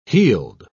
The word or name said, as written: heeled